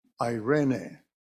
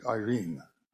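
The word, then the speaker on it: irene (εἰρήνη): In the New Testament, the word irene, often the way it is written in Greek, people tend to pronounce it Irene. It's irene. I-R-A-Y, this is not how it is spelled, this is how it's pronounced, Irene.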